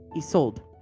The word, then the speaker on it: Isolde: This one is Adrian and I don't know how to say her name correctly. – Isolde? – Isolde. Okay, perfect. Oh my God, it's sold.